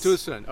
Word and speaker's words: Tucson: The Hyundai Tucson event, yes. – Tucson, okay. – Yes.